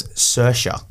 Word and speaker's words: Saoirse: Her name was Saoirse. S-A-O-I-R-S-E. – S-A-O. – I-R-S-E. – Thank you. – Because it's spelled in the Irish way.